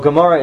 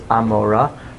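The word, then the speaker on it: Amora (עמורה): So Gomorrah is an improper English translation of the ayin. It's a guttural ayin. – Okay. – In Hebrew it's Amora. But the way you pronounce Amora in the Old Hebrew was Amora. It's like kind of a guttural. – Okay. – And that was transliterated into a G.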